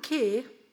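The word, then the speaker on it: ke (καί): Og I grunnteksten så står det et, et, ord Det blir skrevet 'kai' og uttalt 'ke', hvis jeg har forstått det rett. Og dette ordet 'ke' som ikke er med i den norske teksten, det betyr 'også'.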